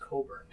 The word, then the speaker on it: Cockburn: I'm like, I'm pretty sure it's pronounced Coburn. The CK is silent, but yes, I will stay away from Cockburn Beach.